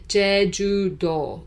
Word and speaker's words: Jeju-do: So it's actually in Korean, Jeju-do, which is Jeju Island.